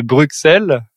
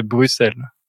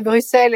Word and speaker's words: Bruxelles: parce qu'on a tendance à le prononcer Bruxelles, alors que c'est Bruxelles. – C'est Bruxelles.